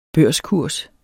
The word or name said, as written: børskurs